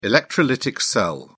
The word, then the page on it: electrolytic cell: British English